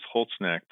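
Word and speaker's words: Holzknecht: So first name is Sean, S-E-A-N, and the last name is Holznecht, and that is spelled just like it sounds. It's H-O-L-Z-K-N-E-C-H-T.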